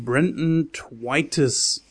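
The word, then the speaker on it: Brenton Thwaites: The Signal erzählt von den Studenten Jonah, gespielt von Bo Knapp, und Nick, an dieser Stelle gespielt von Brandon Twitis, aber das spricht man so aus, die beide am MIT studieren und sich jede freie Minute mit dem Hacken von Computersystemen vertreiben.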